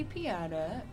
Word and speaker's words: Piechota: Kathy Piatta, pronounced just like it's spelled, P-I-E-C-H-O-T-A.